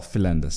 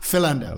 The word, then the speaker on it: Philander: What do your family call yourself? – Oh, Philander, sir. – Philander. Yeah, okay. Tell Sean Pollock. – That's the pronunciation behind it.